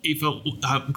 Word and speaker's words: Aoife: Aoife. I tell people it's like FIFA, the soccer organization, without the F.